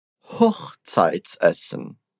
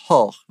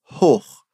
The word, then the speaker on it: Hochzeitsessen: Hochzeitsessen. Hochzeitsessen. – E olha só, lembra que a pronúncia desse O é bem fechada, hein? Você não vai falar hoch, vai falar hoch.